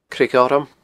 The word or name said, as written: Creag Ghorm